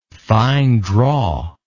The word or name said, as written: fine draw